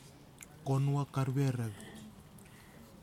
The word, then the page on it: konuwak arwerew: kwamera